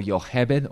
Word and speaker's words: Yocheved: He started to governmentally enforce it, and so Yocheved, or Jochebed, as we would pronounce it,